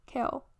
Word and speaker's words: Caol: Kaol, like, see it. Do you see it? It's spelled C-A-O-L. Kaol.